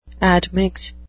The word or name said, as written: admix